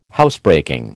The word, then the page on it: Housebreaking: American English